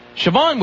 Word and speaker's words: Siobhan: There was this girl named Siobhan, and I'm assuming that's how you pronounce that. – Yep. – Siobhan,